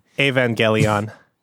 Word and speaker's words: Evangelion: I think that is how you pronounce it. I do not know. – Evangelion? – We can just call it NGE. – Evangelion. – Okay, I got it.